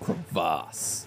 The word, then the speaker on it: crevasse: And he goes, what a waste of crevices. Why is it out of combat? – Mendel looks down. I said it's pronounced crevasse.